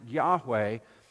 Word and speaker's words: Yahweh: So even though you saw Yod He Vav He, the four Hebrew characters, we pronounce Yahweh, you, you would say Adonai.